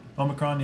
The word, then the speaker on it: Omicron: Is that how you say it? – Omicron, yeah. – Omicron, yeah.